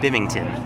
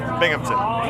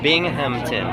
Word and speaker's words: Binghamton: Hey, this is Tim Nordwin from the band OK Go, and you're listening to WHRW Bimington. Is that right? – Binghamton. – Bimington. How do you say it? – Binghamton. – Binghamton. – Yeah. – Oh, I spelled it wrong. How do you spell it? – B-I-N-G.